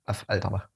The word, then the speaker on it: Affalterbach: Ja gut, dann halt, was, wie Affalterbach? Af, Alter. Genau, so glaube ich, kann man es am besten aussprechen, weil okay. ein A, Doppel F. Ja, Af Alterbach.